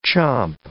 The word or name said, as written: chomp